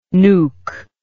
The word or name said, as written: nuque